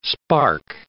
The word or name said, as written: spark